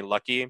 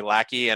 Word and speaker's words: Laki: because my name is spelled L-A-K-I, but I prefer people to call me Lucky, because that's the way, it's just the way someone spell it. And whenever somebody calls me Lackey,